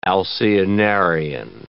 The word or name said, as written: alcyonarian